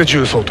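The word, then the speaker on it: 十三: 重曹と 13と書いて重で重曹と読むようになったと。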